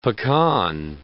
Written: pecan

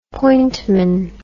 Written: pointman

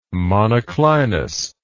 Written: monoclinous

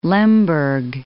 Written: Lemberg

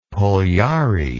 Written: parlyaree

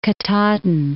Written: Katahdin, Mount

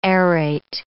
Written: aerate

